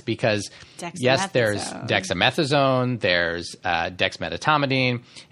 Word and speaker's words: dexmedetomidine: It's dexmedetomidine, not dexmedetomidine. So people leave out that middle syllable.